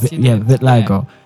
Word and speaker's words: vitiligo: So, something that the audience doesn't know that we know here is that has vitiligo. Am I saying that right? – Yes, vitiligo.